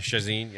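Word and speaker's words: Chacín: I can never say his name right. – Chacin, yep.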